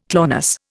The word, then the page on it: clonus: Indian